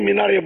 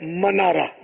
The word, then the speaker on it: منارہ: تو وہی منارا عوام میں منارا ہی کہا جاتا ہے, اب تو خواص بھی مینارا بولتے ہیں, ہو سکتا ہے غلط العام فصیحون کے زمرے میں آ جائے. بہرحال یہ کہ اس کا صحیح تلفظ وہ ہے منارہ, میم پر زبر ہے,